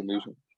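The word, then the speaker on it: Newsome: Yeah, Ozzie Newsman.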